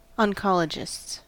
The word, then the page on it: oncologists: American English